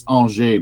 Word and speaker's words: Angers: It's Angers, but it's spelled Angers.